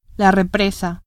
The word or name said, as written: represa